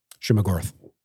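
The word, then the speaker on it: Shuma-Gorath: Shuma Gorath. – Yeah, that's it. Shuma Gorath.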